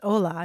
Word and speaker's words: hola: I'm thinking of Spanish, too, which the word starts with an H, although it's hola. You don't pronounce the H.